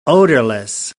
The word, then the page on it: odourless: American English